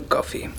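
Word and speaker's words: Coffey: Te llamas John Coffee. Sí, señor jefe. Como el café. Pero se escribe diferente.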